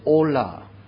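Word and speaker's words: olah: Why is it called in a burnt sacrifice, the Hebrew word is pronounced as ola. Ola, it sounds like Spanish.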